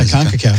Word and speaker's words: CONCACAF: Yeah, CONCACAF.